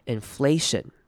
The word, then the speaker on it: inflation: inflation 这个字是大家很容易念错的， inflation 要注意的是中间的 A 念成长母音 A 的声音 A inflation 重音就在 flay 这个音节上面 inflation。